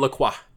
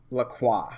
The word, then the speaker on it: LaCroix: LaCroix. – LaCroix. – It's French. And so you have to sound like you've got good health care. And you're confident. – I thought it was LaCroix.